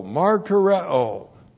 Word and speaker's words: martureo: The verb martureo. M-A-R-T-U-R-E, long O, martyreo.